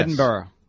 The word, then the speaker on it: Edinburgh: BURGH is pronounced borough. – Yes. Edinburgh. That's how they pronounce it.